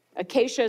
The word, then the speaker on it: acacia: The materials used to build this piece of furniture, acacia wood, acacia is spelled A-C-A-C-I-A, acacia wood, and it was overlaid with bronze.